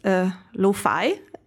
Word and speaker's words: Lufay: So first of all, it's pronounced Lufay. And it's kind of like how you would write it down in Italian, the word lo-fi, so like low fidelity,